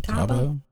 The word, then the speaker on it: tabua: I'm thinking I'm saying it right. T-A-B-U-A. Taba? Taboo? – Taba. Taba. Okay.